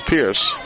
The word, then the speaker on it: Pires: Pierce spells it P-I-R-E-S, by the way, but pronounced it Pierce.